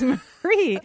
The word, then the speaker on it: Marie: And she would go, I thought your name was Maury. And I go, no, it's Marie.